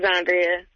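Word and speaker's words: Xondria: This is Xandria.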